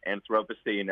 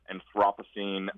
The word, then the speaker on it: Anthropocene: Well, there are a number of debates about this concept, and one of the debates is how you pronounce it. So you hear some people say Anthropocene or Anthropocene, or our British colleagues say Anthropocene.